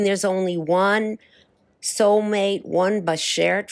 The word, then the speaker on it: beshert: Tell me about the word beshert. Am I pronouncing that right? – Beshert, yes.